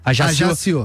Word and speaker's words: Ajaccio: Ajaccio. – Então, Ajaccio da França foi o clube que ele começou. Ele começou, no caso, no México, né? No São Luís e no América, mas Ajaccio foi a primeira experiência dele no futebol europeu.